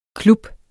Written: klub

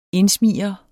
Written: indsmigrende

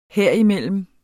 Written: herimellem

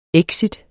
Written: exit

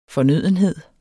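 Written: FORNØDENHEDER

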